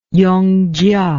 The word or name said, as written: yongjia